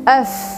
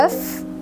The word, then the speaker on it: œuf: Como se fala um ovo em francês? Un œuf, œuf of. E para você falar of, of, e aqui lembrando que eu tô falando no singular, você precisa fazer aquela cara de vômito.